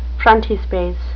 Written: frontispiece

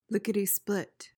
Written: lickety-split

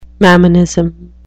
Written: mammonism

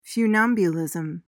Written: funambulism

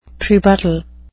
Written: prebuttal

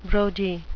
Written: brodie

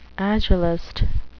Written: agelast

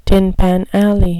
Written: tin pan alley

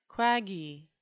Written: quaggy

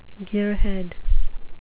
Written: gearhead

